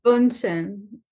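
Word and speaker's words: Bündchen: I googled how to pronounce. – So wait, what? – Bundchen.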